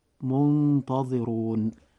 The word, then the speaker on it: منتظرون: وأيضا الراء في آخر كلمة من التلاوة منتظرون، فقد جاء حرف الراء أيضا مضموما، فيجب أن يفخم إذا جاء هكذا فتنطق منتظرون.